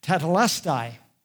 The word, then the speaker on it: tetelestai: It is finished is the English translation of the underlying word, which is pronounced tetelestai.